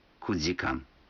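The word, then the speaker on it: кудикан (九時間): Некоторые цифры в этих случаях произносятся не так, как обычно. 4 часа это йодикан, 7 часов ситидикан, а 9 часов кудикан.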